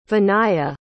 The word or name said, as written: vinaya